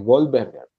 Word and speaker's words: Goldberger: investigando, investigando, investigando, este Goldberger, Goldberger, tenemos que pronunciar como es Goldberger, ¿sí?